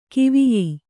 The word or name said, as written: ಗವಯ